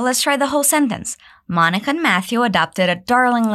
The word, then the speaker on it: Monica: Notice the pronunciation of Monica. We don't say Monica. Instead, we say Monica.